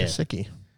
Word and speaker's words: Gesicki: But I got personally, I don't know how to say it, Gesicki? Yeah. – Gesicki.